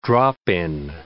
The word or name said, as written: drop-in